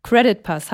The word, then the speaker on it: CreditPass: Credit Pass. Habe ich das jetzt richtig ausgesprochen? – Völlig korrekt, hundertprozentig, ja.